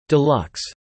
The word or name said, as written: deluxe